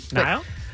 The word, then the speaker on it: Niall: I feel like I've been saying his name wrong all morning. – Niall? – That's right.